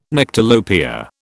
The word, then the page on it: Nyctalopia: British English